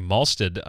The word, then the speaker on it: Mahlstedt: Larry Malstead, I believe, is how his last name is properly pronounced, is doing the ink work along with Tom Yates.